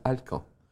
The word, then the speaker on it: Alkan: Yazılışı da Alkan yazılıyor. – Hı hı. Ama okunuş Fransızca Alkan.